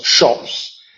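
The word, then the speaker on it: scop: En fin, entonces tenemos esa cultura, y parte de esta cultura son los shops, y no me refiero a las tiendas, sino a ese C-O-P, que se pronuncia shop, que es el especie de bardo, el que aprende los poemas y los repite, y que mantiene los valores de la sociedad.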